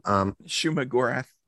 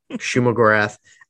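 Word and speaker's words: Shuma-Gorath: Shuma Gorath. – Shuma Gorath.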